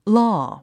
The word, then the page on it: law: lɔ’ː; ロォゥ